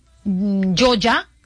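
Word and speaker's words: Yolla: También la aplicación Yoya, se escribe Y-O-L-L-A, y Reptel.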